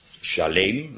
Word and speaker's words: Salem: Salem betekent gewoon vrede, shalom. Shalem, want zo kan het ook uitgesproken worden.